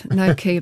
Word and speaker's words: gnocchi: I just googled it. Gnocchi, apparently. I think we've been saying gnocchi.